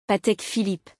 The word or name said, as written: Patek Philippe